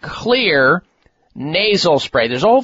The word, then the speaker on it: Xlear: And Clear is spelled X as in X-ray, X-L-E-A-R.